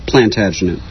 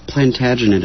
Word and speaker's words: Plantagenet: Plantagenet is English. – Okay, whatever. It's got... Oh, yeah, that's right, because you pronounce the T. If you don't pronounce the T, then it's French.